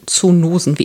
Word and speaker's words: Zoonosen: Und da ging es nämlich genau um solche Zoonosen, wie immer sie ausgesprochen werden. Und sie werden wie der Zoo mit Doppel-O und dann kommen die Nosen.